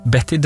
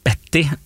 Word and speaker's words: Bette: Betty Davis, det som morsomt at de skriver navnet helt likt, Bette, men uttaler det helt forskjellig. Det altså Bette Midler og Betty Davis, selv om ja, det oppkalt og sånt noe. Betty Midler for eksempel, det høres helt feil ut.